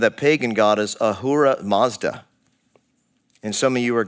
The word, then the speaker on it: Mazda: It's named after the founder Matsuda, that is pronounced Mazda in Japanese.